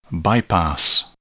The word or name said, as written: bypass